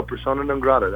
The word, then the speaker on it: Persona Non Grata: No, Persona Non Grata. That's how you pronounce it.